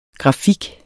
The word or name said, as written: grafik